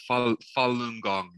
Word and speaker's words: Falun Gong: The Falun Gong. Did I get that? Did I even get remotely close to that right? – Oh, you're lovely. Yeah, Falun Gong. Yeah, absolutely.